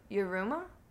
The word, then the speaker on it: Yiruma: River Flows in You by Yiruma. I might be pronouncing it wrong. Y-I-R-U-M-A.